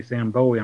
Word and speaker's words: Bowie: Yeah, I was going to say Sam Bowie.